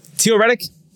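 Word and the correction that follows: Theo Riddick: Theo. – Theo Reddick. – Theo Reddick. I just don't pronounce the TH properly.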